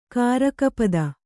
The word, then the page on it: ಕಾರಕಪದ: Kannada